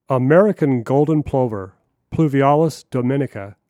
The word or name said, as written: American Golden Plover